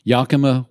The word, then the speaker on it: Yakima: And not sure I'm pronouncing it right, but Yakima, Washington? – I believe that's correct.